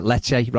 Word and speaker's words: Lecce: Hulmand at Lees, at Lecce, rather.